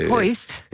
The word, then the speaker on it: Hoist: og så nu ved vi ikke lige, hvordan vi skal udtale, så vi staver det lige, H-O-I-S-T Finans. – Hoist? – Ja, det er ikke lidt svensk.